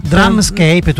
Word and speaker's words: Drumscape: Si è appena concluso proprio davanti a noi il drum o drum, come si pronuncia? – Drumscape.